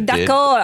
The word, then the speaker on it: d'accord: Je suis d'accord.